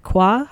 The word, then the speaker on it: Quah: Nick Qua, I think is how you say it, Q-U-A-H. – Yes, that's how you pronounce it, and it's rarely is it ever little.